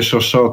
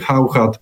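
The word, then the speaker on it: Chauchat: Mogę zdradzić, że w prywatnych rozmowach nigdy nie mówię szoszo, tylko zawsze mówię hauchat, bo jakoś mi się tak bardzo to śmiesznie.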